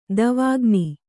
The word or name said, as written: ದವಾಖಾನೆ